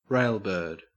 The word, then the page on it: railbird: Australian English